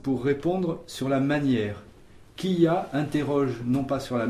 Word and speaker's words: kia: Nous avons vu aussi un petit mot qu'on appellera plus tard un corrélatif et qui se prononce kia, qui s'écrit K-I-A et que l'on prononce kia.